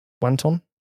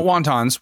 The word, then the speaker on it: wanton: W-A-N-T-O-N. How's that pronounced? Wanton? – Wanton. – Wanton. – Okay. Wanton. Yep. – Yeah, we've got wantons. – Yeah, so, not wontons. We're not eating in a Chinese restaurant. It's wanton. Yeah.